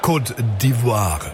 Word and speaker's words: Côte d'Ivoire: Und mittlerweile, wenn ihr alles richtig machen wollt heute Abend, der Start besteht eigentlich darauf, dass wir Côte d'Ivoire sagen. Die französische Aussprache, immerhin die Franzosen, haben das Land ja mehr oder weniger übernommen im 19. Jahrhundert.